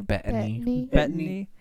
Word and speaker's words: Bettany: Bettany.